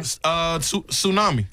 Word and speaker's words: tsunami: This has a silent letter in it. – Okay. – Tsunami. – Tsunami. – Tsunami. Yeah. – Tsunami. It sure does. – Now, some people might say tsunami. – Tsunami. – Tsunami. – Yeah. – Somebody might say that. No. They'd be wrong.